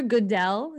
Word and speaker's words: Goodell: So if you guys have ever watched the NFL draft, it's really funny because right as it starts, you'll see Roger Goodell.